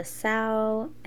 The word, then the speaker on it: Sao: It's called Silk with a period at the end by Samantha Sao. And my last name is spelled S-A-O.